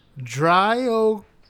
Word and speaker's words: Dryocopus: Say it like you would say in like an Italian. – Yes. Latin does sound best when it's pronounced as if you were an Italian. Genus is Dryocopus. – Dryocopus?